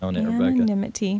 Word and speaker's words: anonymity: Did I say it right? Anonymity? Did I just say that? Anonymity. – You are nailing it, anonymity.